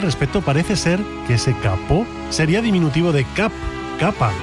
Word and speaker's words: capot: El alemán tomó prestado ese kaputt en el siglo XVII del francés capot, hoy pronunciado capó concretamente,